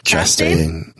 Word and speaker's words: Chastain: Chastain.